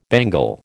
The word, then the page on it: bangle: American English